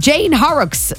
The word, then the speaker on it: Jane Horrocks: And Jane Horrocks.